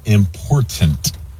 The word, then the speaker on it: important: Clay Travis, you really enjoyed the word important. The problem is you're mispronouncing it. It is important. Like my girlfriend and wife say, mind the T's.